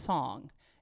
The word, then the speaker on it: Cong: His name is spelled in English because it's Chinese, C-O-N-G, so it looks like Kong, but it's pronounced Song, as he would say, Song, Song Song.